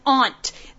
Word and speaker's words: aunt: It's spelled A-N-T. – If you're talking about Ant-Man, you're awesome. If you're talking about rant, you've got it. But it's an aunt. The sister of your mother or your father is your aunt.